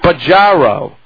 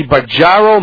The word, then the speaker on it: Bajaro: Actually, it's pronounced Baggiaro. – Baggiaro. – Baggiaro. B-A-J-A-R-O. – The Baggiaro Method.